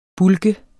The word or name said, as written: bulk